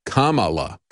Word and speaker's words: Kamala: I'm sorry, it's Kamala. That's the way you remember it, that little jingle. I have to play that in the back of my head to remember how to pronounce her name, because I can never remember which syllable to put the emphasis on to pronounce her name correctly.